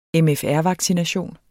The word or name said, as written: MFR-vaccination